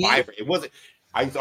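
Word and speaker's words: Vivrant: It's Vibrant because you have to sound out the syllables.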